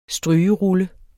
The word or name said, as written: strygerulle